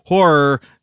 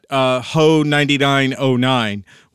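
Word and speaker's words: HO99O9: They even gave a whole spiel when I saw them open for Three Teeth, how it is Horror and not Ho 9909, which is what somebody called them.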